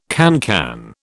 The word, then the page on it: cancan: British English